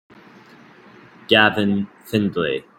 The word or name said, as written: Gavin Findlay